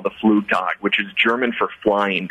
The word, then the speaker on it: Flugtag: That's a Flugtag, yeah. – A Flugtag. Excuse, I didn't know the German pronunciation of this.